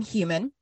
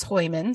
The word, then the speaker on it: Heuman: And I'm going to do my damnedest, because I did a lot of phonetic spellings of these words, but in America, you would pronounce her last name Human. – I love it. – The German pronunciation is Heuman, so that is how I'm going to pronounce it.